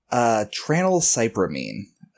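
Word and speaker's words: tranylcypromine: 10-year-long prescription of triclopamine? That's my best take on it. – I don't even know how to spell that. – Painkillers, if I remember right? – Tranylcypramine. It is an antidepressant.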